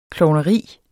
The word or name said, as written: klovneri